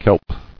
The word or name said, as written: Kelp